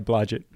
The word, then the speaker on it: Blodgett: Yeah, Blodgett.